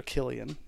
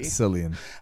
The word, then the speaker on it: Cillian: And starring, do you pronounce it Cillian or Killian Murphy? – Cillian.